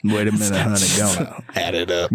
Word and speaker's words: Ghana: Dad's from Ghana, though. – Ghana. – So, Ghana. Yeah. – Not here, Ghana. – Not here, Ghana. Yeah.